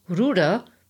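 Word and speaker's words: Ruder: The only thing that comes to mind is Ruda, the German word for rudder, pronounced the same way but woefully inappropriate for a shoe.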